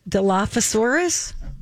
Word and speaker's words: Dilophosaurus: It's a Dilophosaurus.